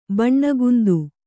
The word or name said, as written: ಬಣ್ಣಗುಂದು